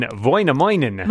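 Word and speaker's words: Väinämöinen: It's V-A-I-N-A-M-O-I-N-E-N, Voinaminen.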